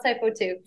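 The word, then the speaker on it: Hypo2: With the, is it HYPO or do we pronounce hypo too? – We pronounce hypo two.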